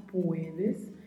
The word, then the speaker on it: puedes: Cuidado ao pronunciar, pois a letra U precisa ser dita de maneira bem clara, puedes, e o D, que é pronunciado com este som mesmo, de D e não de D, como nós falamos no português. Puedes.